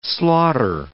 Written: slaughter

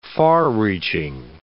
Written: far-reaching

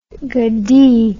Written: gadi

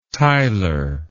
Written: theiler

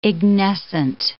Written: ignescent

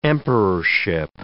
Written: emperorship

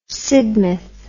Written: sidmouth

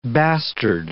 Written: bastard

